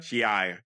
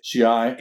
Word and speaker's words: Shi'ar: Shi'ar. – Shi'ar.